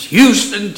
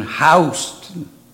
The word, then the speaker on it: Houston: You know, in Texas, it's Houston, Texas. Warren Robins was in Houston. That was the county in Georgia. Spell the same. Not pronounce the same.